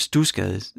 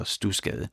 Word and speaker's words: Studsgade: så skal du bare lige huske det der med, at når jeg siger stusgade, så staves det S-T-U-D-S, altså sådan studsgade. Men man udtaler det altså stusgade. Lidt ligesom det hedder ikke Bredstrup, det hedder Brastrup. Jeg kan ikke sige hvorfor, det hedder bare Stusgade. S-T-U-D-S-G-A-D-E, snabelag radio4.dk, og så lander din e-mail lige i min inbox.